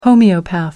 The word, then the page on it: homeopath: American English